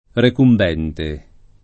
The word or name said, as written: recumbente